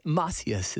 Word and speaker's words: Macias: But Macias is like Cassius Clay.